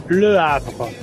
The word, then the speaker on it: Le Havre: Bueno, queda mi pronunciación como se dice. Le Havre. Le Havre.